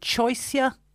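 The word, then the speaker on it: Choisya: A golden, is it choicea? – Yeah, you're spot on.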